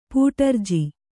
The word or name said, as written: ಪಟ್ಟರಾಜ